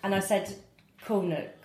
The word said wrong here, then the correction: Kaunas: Kaunas, yeah.